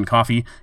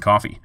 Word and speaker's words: Coffey: I'm your host, Kevin Coffey, and you can follow me on Twitter as @OmahaMusicGuy, or find my page on Facebook by searching for my name, Kevin Coffey. My last name is spelled C-O-F-F-E-Y.